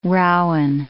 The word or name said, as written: rowan